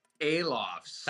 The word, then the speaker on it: Alofs: Alofs.